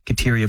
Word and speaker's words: Kateri: Kateri, of course, being the way that they would pronounce Catherine.